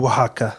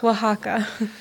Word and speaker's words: Oaxaca: Oaxaca. – Oaxaca.